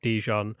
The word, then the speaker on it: Dijon: Is it Dijon? – Dijon? Dijon. How do you say it? I don't know. Dijon, like the mustard. Like the mustard, yeah.